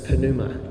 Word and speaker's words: pneuma: So to define spirit, the Greek word for spirit is pneuma.